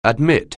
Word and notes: admit: anglais

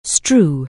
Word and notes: strew: anglais

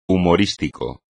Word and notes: humorístico: espagnol

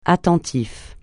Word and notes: attentif: francais

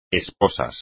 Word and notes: esposas: espagnol

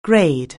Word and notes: grade: anglais

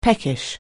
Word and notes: peckish: anglais

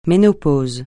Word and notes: ménopause: francais

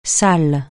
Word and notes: salle: francais

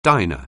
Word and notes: diner: anglais

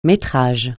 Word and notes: métrage: francais

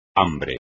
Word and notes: hambre: espagnol

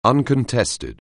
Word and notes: uncontested: anglais

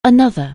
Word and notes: another: anglais

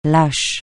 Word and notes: lâche: francais

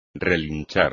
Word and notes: relinchar: espagnol

